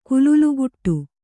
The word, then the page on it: ಕುಲುಲುಗುಟ್ಟು: kululugaṭṭu